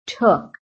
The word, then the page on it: TOOK: American English